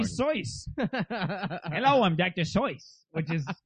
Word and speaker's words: Seuss: Or you would think, but that's the thing is that Seuss is spelled S-U-E-S-S, but it's supposed to be pronounced Soyce. – It's S-E-U-S-S. – S-E-U.